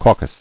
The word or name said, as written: Caucused